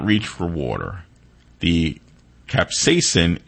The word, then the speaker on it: capsaicin: Capsaicin.